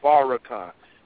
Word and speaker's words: Farrakhan: It's Farrakhan.